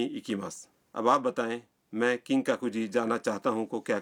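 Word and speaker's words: کنگ ککو جی (金閣寺): مندر کے نام کا تلفظ ہے کنگ ککو جی. کنگ ککو جی.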